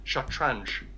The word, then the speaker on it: shatranj: It, the letters weren't used in Persian, so they pronounced the, the game shatranj because they couldn't. They didn't have really a, a, ch noise or really even an ng noise.